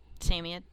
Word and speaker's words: Samoyed: And the number one is the Samoyed. Or Samoyed, depends on how you say it. – How do you say it? – Samoyed.